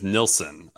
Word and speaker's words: Nilsen: It's, it's spelled a little differently, but you're right. It is Nilsson.